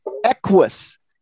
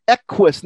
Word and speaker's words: equus: Now, this word is confusing if you see the spelling of it, because it's spelled E-Q-U-U-S. And a lot of people want to pronounce both U's. You want to say something like equus, but no. That first U goes right with the Q. So it's kind of silent. So it's equus. Equus.